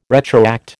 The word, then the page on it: retroact: American English